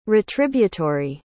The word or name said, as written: retributory